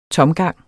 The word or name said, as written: tomgang